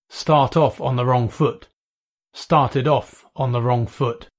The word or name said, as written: start off on the wrong foot